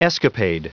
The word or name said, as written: escapade